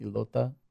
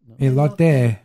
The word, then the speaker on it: elote: Elota? – Elote.